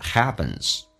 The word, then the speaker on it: happens: what 这个单词可以发成这个发音 what，阻塞一下 what happens， happen p 发 b 这个发音要被浊化，两个元音中间所加的辅音要被浊化，在美式发音当中，而且后面加的 s 要发 z 这个发音叫 what happens。